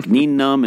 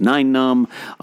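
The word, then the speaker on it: Nien Nunb: A lot of things I believed in Star Wars, like Neenum is Nine-Num.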